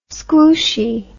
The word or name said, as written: squooshy